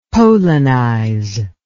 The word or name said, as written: polonize